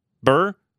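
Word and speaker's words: Bure: Is it Buer? Am I saying that right? Burr. Candace Cameron Burr.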